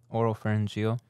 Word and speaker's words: oropharyngeal: Oropharyngeal.